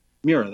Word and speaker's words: Mura: Is it, how do you pronounce her name? Mura? – Mura, that's correct.